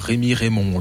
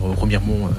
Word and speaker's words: Remiremont: Et en termes d'anecdote, tous les artistes qu'on a fait venir, quand on leur disait que c'était à Remiremont, ils disaient toujours Rémi Raymond, au lieu de dire Remiremont d'un seul mot. Donc j'ai dit, bah, pour rendre hommage à tous ces artistes parisiens et autres artistes qui ne savaient pas prononcer Remiremont correctement, on va l'appeler le trophée Rémi et Raymond, voilà, en hommage à tous nos artistes qui sont venus et qui ne savaient pas prononcer le mot Remiremont en un seul mot.